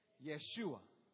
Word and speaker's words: Yeshua: Su nombre era Yeshua. A ver, pronúncienlo. Yeshua. Así es como se dice la palabra Jesús en arameo.